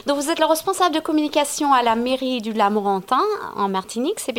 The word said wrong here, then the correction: Lamentin: bien ça Alors, c'est la mairie du Lamentin. – Alors, on le prononce mal, désolé. – L-A-M-E-N-T-I-N. E e euh, le Lamentin, la ville du Lamentin en Martinique.